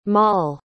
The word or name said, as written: molle